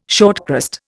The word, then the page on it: shortcrust: Indian